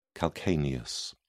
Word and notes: calcaneus: British English

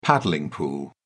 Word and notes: paddling pool: British English

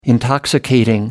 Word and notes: intoxicating: American English

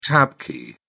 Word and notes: tab key: British English

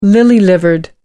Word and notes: lily livered: American English

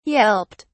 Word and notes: yelped: British English

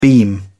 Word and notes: beam: British English

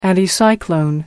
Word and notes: anticyclone: American English

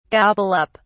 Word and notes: gobble up: British English

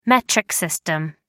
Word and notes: metric system: British English

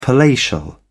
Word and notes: palatial: British English

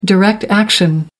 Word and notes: direct action: American English

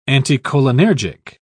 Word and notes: anticholinergic: British English

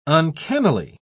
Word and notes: uncannily: British English

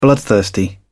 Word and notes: bloodthirsty: British English

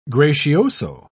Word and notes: gracioso: British English

